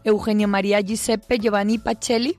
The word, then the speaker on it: Eugenio María Giuseppe Giovanni Pacelli: Y hoy, como ha comentado el padre José Ramón, traemos a colación a Eugenio María Giuseppe Giovanni Pacelli, lo he dicho bien, ¿verdad? – Sí, perfecto.